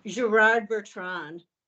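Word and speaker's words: Gérard Bertrand: Gerard Bertrand.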